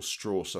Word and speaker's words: Strauss: Louis Strauss. Is that his name, Louis Strauss? – Yeah, or Strauss, I think you pronounced it differently.